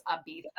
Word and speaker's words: Ibiza: But it's really cool because the woman that is teaching this course, she lives in Ibiza, which she pronounced Ibiza in Spain.